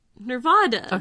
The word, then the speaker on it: Nevada: No, say it right. – Nevada. – Okay, thank you.